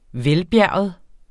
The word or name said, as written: VELBJERGET